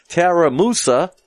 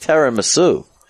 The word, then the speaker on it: tiramisu: Episode 172, we have Tara Musa instead of Tiramisu.